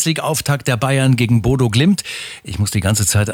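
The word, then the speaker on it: Bodø/Glimt: Ja, aber eigentlich spricht man die ja Bodo Glimt aus. Das Sensationsteam aus Norwegen.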